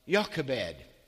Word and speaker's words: Jochebed: The name of Amram's wife was Jochebed. That's how you pronounce it, even though in the English it's spelled with a J. In Hebrew, it's Jochebed, the daughter of Levi, who was born to Levi in Egypt.